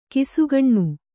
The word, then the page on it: ಕಿಸುಗಣ್ಣು: kisugaṇṇu